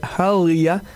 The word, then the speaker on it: Harlea: Ma tasse de thé se poursuit avec une nouveauté de la chanteuse Halia. Ça s'écrit H-A-R-L-E-A.